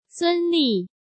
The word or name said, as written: Sun Li